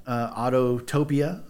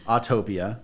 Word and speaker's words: Autopia: But Autotopia. I'm kidding. Autopia. That's how you say it, because that's how it's spelled.